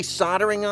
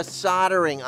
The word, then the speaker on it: soldering: Are you trying to say soldering iron? – Soldering or soldiering.